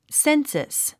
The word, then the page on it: census: sénsəs; センサス